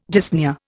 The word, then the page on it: dyspnoea: Indian